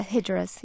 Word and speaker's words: Hijras: Salman Rushdie writes about the Hijras, if I said that correctly? – Yeah, Hijras, yes.